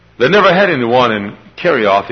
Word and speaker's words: Kerioth: We'd spell it K-E-R-I-O-T-H, Kerioth.